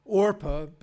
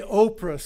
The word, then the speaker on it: Orpah: I should mention, I think it's the case that famous television celebrity Oprah was actually named for Orpah, but her mother misspelled it, mispronounced it. And so if I say Oprah sometime instead of Orpah, that's why.